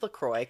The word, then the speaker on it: La Croix: Bitch, it's La Croix. Come on. It's La Croix. Everybody calm down. It's like St. Croix. – Yeah. – We don't say St. Croix.